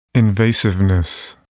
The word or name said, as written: invasiveness